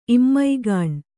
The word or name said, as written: ಇಮ್ಮೈಗಾಣ್